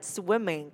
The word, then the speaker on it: swimming: Ở đây mọi người giúp ha, không đọc là swim, swim. Mình đã là âm y ngắn trong từ e, nó sẽ đọc là swim, swim, swimming, swimming, swimming.